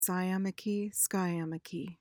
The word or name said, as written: sciamachy